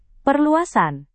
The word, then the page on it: perluasan: Indonesian